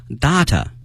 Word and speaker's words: data: Chinese researchers 中国的研究人员 have 后面分析我们讲过 analyze analyze 数据，我们学过 data，在澳大利亚会把这个词念成了 data，发音都是可以的哈，不同的国家对这个词的发音是不同的。